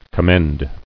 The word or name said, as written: Commendable